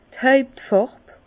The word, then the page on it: d' Hàuptfàrb: Bas-Rhin; Herrlisheim